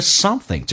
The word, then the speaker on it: something: Have something 就 eat something，吃一些 something 这个单词，记住又咬舌又往后鼻音 something。